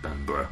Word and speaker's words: Bamburgh: You also pointed out that I was pronouncing Bamburgh wrong, because I was saying Bamba instead of Bamburgh, like Edinburgh.